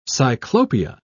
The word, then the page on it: cyclopia: English; British English